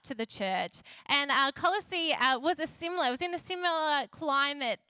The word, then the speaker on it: Colossae: Everyone say Colossae, did I say that right? Colossae, everyone say Colossae, the church in Colossae.